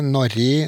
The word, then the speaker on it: Norge: Og det har skjedd òg i stedsnavnet Norge, så det fins fra eldre kilder som Norge, Norri, eh, mange varianter, men lite uttalt G.